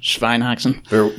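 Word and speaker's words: Schweinshaxe: Yeah, the Schweinshaxa, which Schweinhaxen. – Yeah, I mean, looks. – Yes, Aaron mispronounced something.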